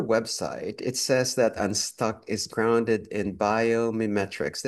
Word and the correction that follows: Biomimicry: Did I pronounce that correctly? – Biomimicry.